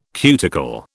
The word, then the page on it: cuticle: British English